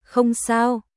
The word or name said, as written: Không sao